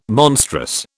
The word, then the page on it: monstrous: British English